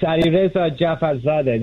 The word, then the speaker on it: Alireza Jafarzadeh: I think I said it right. Alireza, did I say it right? – Perfect. It's Alireza Jafarzadeh. This is the best pronunciation I've gotten the whole day.